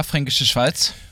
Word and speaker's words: Fränkische Schweiz: And so the Frankische Schweiz in Bavaria. Yeah, Fränkische Schweiz. I said it correctly, no? – Yeah.